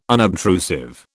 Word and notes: unobtrusive: British English

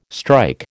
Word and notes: strike: American English

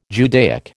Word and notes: Judaic: American English

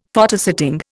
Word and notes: Photosetting: Indian

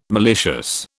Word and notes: malicious: British English

